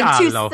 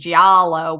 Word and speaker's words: giallo: Giallo. It's like Giallo. Two syllables, and no matter how hard I try, I have to concentrate because I'm so used to saying Giallo, which is like the lazy American way to say it.